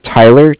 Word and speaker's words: Tyler: Okay, my name is Tyler, T-Y-L-E-R, and I'm calling with Cardiff. It's spelled C-A-R-D-I-F-F, as in Frank Frank.